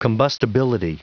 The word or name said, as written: combustibility